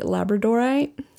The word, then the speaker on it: labradorite: It's a yoni labradorite.